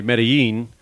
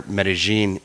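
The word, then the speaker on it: Medellín: Really, in Spanish, the double L has a Y pronunciation, but specifically in Colombia and even more specifically in the Antioquia region where Medellin is, the double L goes more from a Y into a J.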